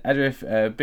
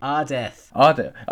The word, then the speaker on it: Ardeth: Adrif, big Ardeth.